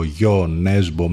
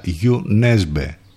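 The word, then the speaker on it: Jo Nesbø: Εν τω μεταξύ, να ξέρετε ότι αυτή είναι η κανονική προφορά του ονόματός του, γιατί το έχουμε ακούσει όλοι σε ένα σωρό διαφορετικές εκδοχές, από Γιο Νέσμπο μέχρι Τζον Νέσμπε. Τελικά Γιου Νέσμπε προφέρεται, να το ξέρετε αυτό.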